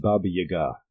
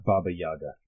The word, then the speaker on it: Baba Yaga: It's pronounced Baba Yaga, not Baba Yaga. Baba Yaga. Emphasize the second act.